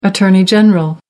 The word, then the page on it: attorney general: American English